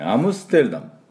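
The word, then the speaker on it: Amsterdam: あとはですね、アムステルダム、アムステルダムの発音ですね、どんな感じになるかというとアムステルダム、アムステルダムっていう音になります。最初の音がおみゃーん、ここにアクセントがあってアムステルで、ステーですね、チューシですね。で、ダム。これもおみゃーです、ね。で、アムステルダム。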